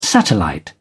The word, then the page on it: satellite: British English